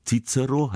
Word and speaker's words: Cicero: Im klassischen Latein, also zu Zeiten von Cäsar und Cicero, da wurde das C wie ein K ausgesprochen. Cicero heißt eigentlich Cicero, was nichts anderes bedeutet als Kichererbse, ein Spitzname für den großen Philosophen und Staatsmann, der eigentlich Marcus Tullius geheißen hat.